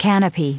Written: canopy

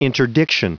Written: interdiction

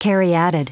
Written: caryatid

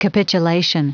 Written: capitulation

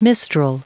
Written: mistral

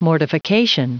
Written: mortification

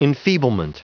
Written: enfeeblement

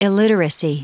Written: illiteracy

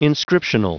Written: inscriptional

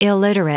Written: illiterate

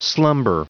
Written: slumber

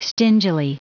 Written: stingily

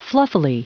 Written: fluffily

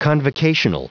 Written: convocational

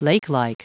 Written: lakelike

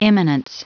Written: imminence

